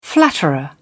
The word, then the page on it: flatterer: British English